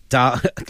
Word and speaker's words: Daga: Daga. – Like an arga. – Like the holiday. Saga.